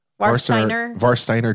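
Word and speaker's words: Warsteiner: They've got the Beck's Pilsner, the Varsteiner. Did I say that right? – Varsteiner Dunkel.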